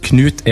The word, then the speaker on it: Knut: So, it's spelled K-N-U-T, then Emil, then what looks to me like Johannesson, and then L-I-E at the end. – Johansson. – Yeah, that's correct. It's actually pronounced Knut Emil, Johannesson Lee. – Knut? – Yeah, Knut. – Cool. – See, I thought, for some reason, I thought the K would be silent.